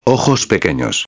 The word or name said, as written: pequeños